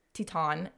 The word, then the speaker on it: Teton: Anyways, they had narrowed down a specific area in Grand Teton. It's actually Teton. – Really? I thought it was Teton. – The news anchor was saying Teton today.